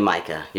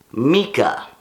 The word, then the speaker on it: Mika: And so, I've been calling you Micah. Your name is pronounced, because I now know that you are of German descent, Mika.